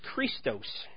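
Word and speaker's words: chrestos: The Greek word for kindness is Christos. Maybe that's how you say it. It's C-H-R-E-S-T-O-S. C-H-R-E-S-T-O-S.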